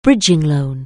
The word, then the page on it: bridging loan: anglais